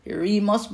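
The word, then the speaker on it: Eremos (ἔρημος): E-R-E-M-O-S. Eremos, but it's pronounced E-R-A-Y-M-O-S. Eremos.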